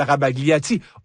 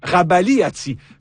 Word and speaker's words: Rabagliati: L'auteur des bandes dessinées de la série Paul, Michel Rabagliati, aussi prononcé Rabagliati, fait son entrée dans le Robert Illustré 2027.